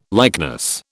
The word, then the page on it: likeness: British English